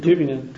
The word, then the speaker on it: Tübingen: How do you pronounce Tübingen? – In Tübingen. – Tübingen? – Yeah.